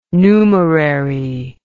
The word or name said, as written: numerary